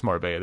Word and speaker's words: Marbella: It is Marbella, the double L.